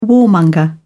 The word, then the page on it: warmonger: British English